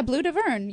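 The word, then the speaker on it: Bleu d'Auvergne: Now, I'm not pronouncing this right. Blue d'Auvergne, is it? – Yeah, Blue d'Auvergne, yes.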